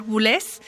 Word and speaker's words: Boulez: Ha dejado gran escuela en otros compositores y de los que a él le influenciaron particularmente al inicio de su carrera fue Pierre Boulez o Pierre Boulez, como nuestra querida Dulce Wet nos ha dicho que se pronuncia correctamente.